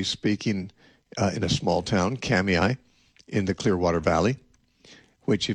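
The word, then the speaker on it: Kamiah: it doesn't look like it should be pronounced Kamiai. It looks like it should be pronounced Kamaya. It's K-A-M-I-A-H. I guez it's a Nez Perce Indian word, and they pronounce it Kamiai.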